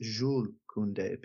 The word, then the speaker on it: Jules Koundé: But um, really quick, my fiance told me that it should actually be pronounced Jules Koundé because he's French and you don't pronounce the S.